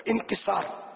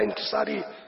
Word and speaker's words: انکسار: ہر عالم طالب علم تو ہوتا ہے, کہ انکساری جو انکسار, اصل لفظ ہے تلفظ انکسار, ہمارے یہاں انکساری بولا جاتا ہے, لفظ جو انکسار,